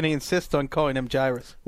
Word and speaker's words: Jairus: So the people on the network side approached us, the ATL folk, because we're knowledgeable, and said, how do you pronounce that? Turns out it's Wes. – It's Jairus, and this has come up on the podcast before.